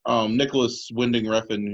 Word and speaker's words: Nicholas Winding Refn: Nicholas Winding Refn, who's the director of...